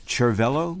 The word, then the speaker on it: Cervello: And what we're going to hear is a group called Cervello, or I guess Cervello would be the pronunciation, C-E-R-V-E-L-L-O.